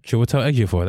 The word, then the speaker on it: Chiwetel Ejiofor: Chiwetel Ejiofor. That's how you say it.